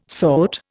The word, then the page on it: sort: Indian